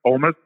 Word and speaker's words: Houmes: And then my name again is Chip Oumes. The last name is spelled H-O-U-M-E-S.